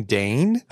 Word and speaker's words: Dayne: And I was like Dan Logan? And they're like, you mean Dane? And I realized in my accent, have I been mispronouncing Dan's name for the last 2 years? – Well, I don't know.